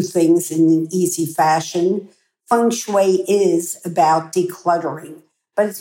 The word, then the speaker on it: feng shui: Now, how do you say feng shui? I've heard so many people pronounce it in so many different ways. The fu, the feng, the hu. It's feng shui.